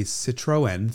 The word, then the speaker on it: Citroën: So you have a Citroën, three syllables.